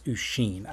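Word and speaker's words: Oisín: So I think in Ireland, when Yeats writes about Usheen, I know how that's pronounced. That's Usheen.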